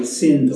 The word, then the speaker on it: Sindo: How do you pronounce the gallery? Shin? – S-I-N-D-O. Sindo.